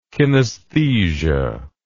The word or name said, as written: kinaesthesia